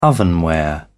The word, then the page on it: ovenware: British English